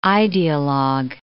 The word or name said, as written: ideologue